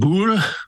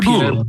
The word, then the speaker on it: Boulle: How is it pronounced? Boulle? – Pierre Boulle. – Boulle.